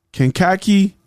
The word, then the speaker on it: Kankakee: I'm in the city of, let me make sure I pronounce this right, Kankakee, Illinois.